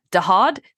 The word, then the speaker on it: Dahod: So in Dahud, okay, I'm sorry. I have to apologize for my Indian pronunciations. But Dahod, that's what it is. It's Dahod.